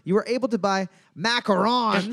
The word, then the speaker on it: macarons: You were able to buy, I think it's pronounced macarons, but I hate the French, as I am one.